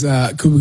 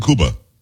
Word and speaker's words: Cuba: Why are you saying Cuba? – Huh? – It's Cuba. It's not Cuba Gooding Jr. It's definitely Cuba. Okay. He pronounces like the country.